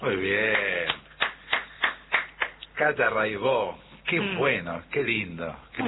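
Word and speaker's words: Raybaut: Cata Raibó. Se escribe Raibaut, métanse, búsquenla, Rai con Y, Baut con B larga. Catalina Raibaut, Raibó se dice, y bueno, cotidiano son 10 canciones, hay un documentalito muy lindo.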